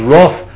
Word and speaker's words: wrath: That's his future, because it says, for God's wrath remains on him. How do you pronounce that, wrath or wrath? – Wrath.